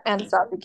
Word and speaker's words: Anasazi: So they said that their only choice after weighing all their options was to send him to the Anasazi, am I pronouncing this correctly? – Yeah, Anasazi camp.